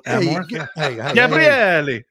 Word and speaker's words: Gabriele: So it's only a matter of time before this story of Father Gabriele Amorth hit the big screen. – Gabriele? – Yeah, that's what it is. That's right there. – Father Gabriel. Gabriel Gabriele. Gabriele. That's the one for me.